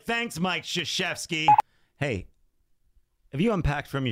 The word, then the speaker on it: Krzyzewski: Spelled with a K. – It's crazy, right? – Right. Not a S-H. – Krzyzewski. I'm trying to teach my son like how to pronounce words and letters and put things together. How am I supposed to teach him that? – Exactly. – Miles, spell Krzyzewski. S-H, it's a sh sound.